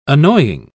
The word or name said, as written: annoying